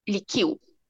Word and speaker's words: lichiu: Și la altă pizzerie am găsit, să vedem dacă pronunț corect, lichiu. Perfect. Lichiu? Da, da lichiu? da, da. Lichiu. – lichiu. Ok, deci este cu U.